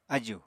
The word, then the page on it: ájù: apatani